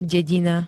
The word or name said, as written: dedina